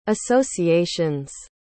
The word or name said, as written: associations